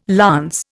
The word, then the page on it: lance: Indian